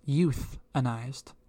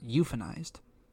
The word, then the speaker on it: euthanized: They say it's euthanized, not euphanized.